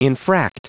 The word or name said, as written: infract